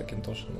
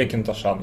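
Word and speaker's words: Auchentoshan: очень сложно пишется просто, да, на самом деле он просто Акинтошин называется. Или Акинтошан, я, если честно, не помню, куда там ударение, но, скорее всего, на третий слог.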